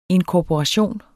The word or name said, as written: inkorporation